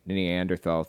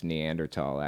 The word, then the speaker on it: Neanderthal: His critics went far beyond Democrats, who surely made themselves vocal in opposition to Texas reopening, with President Biden calling it Neanderthal thinking. I think it's pronounced Neanderthal, actually. I've been mispronouncing it this whole time.